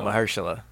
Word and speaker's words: Mahershala: How you say it again? – I don't know. – Mahershala. – Mahershala. For real? Mahershala.